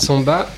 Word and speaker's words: Sembat: Marcel Samba, euh, ce n'est pas l'inventeur de la samba comme on a rigolé tout à l'heure. Désolé de vous décevoir. Et ça s'écrit d'ailleurs S-E-M-B-A-T.